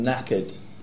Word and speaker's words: knackered: Okay, one that I use quite a lot is if you're really tired, you say, oh, I'm knackered. Knackered, and it's spelled K-N, so it's knackered, but it's a silent K.